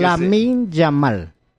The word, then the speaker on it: Lamine Yamal: Fabián Ruiz Peña y Yamil Maguad son dos hombres que Lamine Yamal. Lamine se escribe. Lea, ahí está, Lamine. Lamine Yamal. – No es Yamil Maguad. – Yes. No, Lamine Yamal.